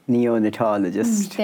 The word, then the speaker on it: Neonatologist: Neonatologist.